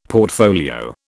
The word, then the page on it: portfolio: British English